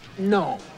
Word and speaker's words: gnome: First of all, if you were a gnome, you would know that the G in gnome is silent. It's gnome, okay? – No, it's gnat.